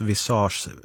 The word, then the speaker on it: Visage: PS, visage uttalas visage, ansikte på franska.